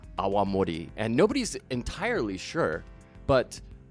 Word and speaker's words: awamori: And that's one of the theories behind why awamori is called awamori. You got to pronounce that W. If you don't pronounce a W, nobody understands what you're talking about.